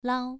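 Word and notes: lao: tone 2

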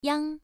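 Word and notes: yang: tone 1

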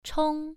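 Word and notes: chong: tone 1

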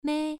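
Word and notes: mei: tone 1

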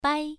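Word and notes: bai: tone 1

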